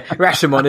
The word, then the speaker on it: Rashomon: Yeah, Rashomon is the pronunciation.